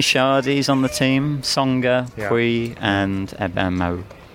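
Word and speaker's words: Pouille: I think Puy. Did I say that right? – Puy. It's more of a... Puy. More of... Imagine there's a Y in the L's.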